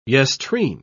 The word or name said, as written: yestreen